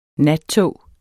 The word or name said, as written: nattog